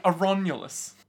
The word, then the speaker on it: Aronulus: Aronulus. – And Remus. – No, not quite. It's more like Aronulus. Yeah. – Aronulus. Can I get the spelling? – A-R-O-N-U-L-O-U-S. – Aronulus. – Aronulus. Yeah. It's pronounced Aronulus.